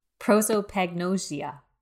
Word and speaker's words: prosopagnosia: Prosopagnosia.